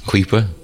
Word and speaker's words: Kuiper: Oh, Kuiper, K-U-I-P-E-R.